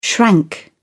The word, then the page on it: shrank: British English